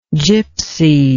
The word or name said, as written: gipsyhood